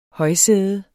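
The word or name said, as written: HØJSÆDER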